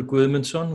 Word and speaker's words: Gudmundsson: Ingolfur Gudmundsson would be the Icelandic pronunciation, but nobody could say it like that.